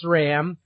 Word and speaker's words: SRAM: For those of you that use SRAM components, there is no H in between the S and the R.